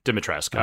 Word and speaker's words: Dimitrescu: You don't pronounce the U. – Okay. Dimitrescu.